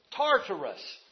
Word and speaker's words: Tartarus: Because it is Tartar. See, I can't even get it out. I've had Tartars for so long that it is Tartarus is its proper pronunciation.